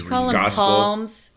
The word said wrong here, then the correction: Psalms: Palms. – Psalms. – Psalms. – The P is silent.